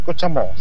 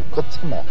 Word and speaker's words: Cochamó: No se dice Cochamó, se dice Cochamó. – Cochamó, ok.